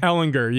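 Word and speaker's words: Ehlinger: At least based on what I'm watching right now, Sam Ellinger is not an NFL quarterback. – Yeah, look, he's definitely young. – Is it Ellinger? – Ellinger, yeah.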